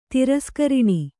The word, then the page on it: ತಿರಸ್ಕರಣ: tiraskaraṇa